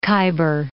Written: Khyber Pass

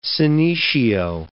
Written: senecio

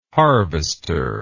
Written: harvester

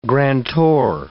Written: grantor

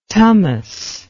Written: tamas

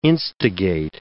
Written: instigate

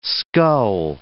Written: skull

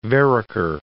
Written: Vereker

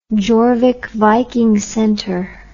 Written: jorvik viking centre

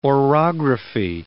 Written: orography

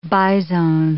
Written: bizone

